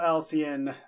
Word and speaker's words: Alcyone: I am Daniel Alcyon. Our last name is spelled A-L-C-Y-O-N-E.